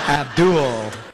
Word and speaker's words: Abdul: We gotta say it like that, man. It's creepy. – Abdul. – That's how it's pronounced. – Holds on to the L a little too long.